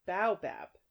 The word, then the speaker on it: baobab: The way it was spelled here was B-A-W, like as the phonetic pronunciation, which I assumed would be pronounced ba, not bow. But then I listened, and it's bow. Which makes more sense, given that it's spelled B-A-O.